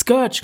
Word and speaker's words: Scourge: Scourge, glaube ich.